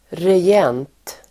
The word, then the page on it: regent: [rej'en:t]